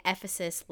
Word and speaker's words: Ephesus: If actually, in order to in order to remember the pronunciation of Ephesus, we've been saying Ephesus like emphasis.